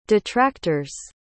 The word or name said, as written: detractor's